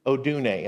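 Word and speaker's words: odune (ὀδύνη): The word pangs there in the scripture translates from the Greek word odune, and I looked that up. That is how you pronounce that.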